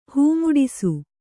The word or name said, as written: ಹೂಮುಡಿಸು